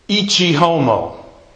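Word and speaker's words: ecce homo: In Latin, It's ichi homo.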